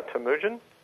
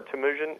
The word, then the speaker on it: Temujin: Temujin or Temujin. It's Genghis Khan's real name.